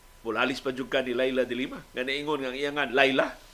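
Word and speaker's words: Leila de Lima: di ko moingon ko ninyo nga kining akong pronunciation nga Laila de Lima gikan ni mismo ni Laila de Lima na ang iyang spelling Laila de Lima pero ang actual pronunciation Laila de Lima.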